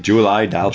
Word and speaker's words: Julie Delpy: Julie Delpy.